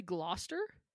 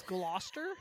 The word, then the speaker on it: Gloucester: It's truly Gloucester. – Yeah. – Oh my God! This is how it's spelled. Gloucestershire. – Yep. – That's Gloucester? – Yeah.